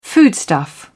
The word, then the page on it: foodstuff: British English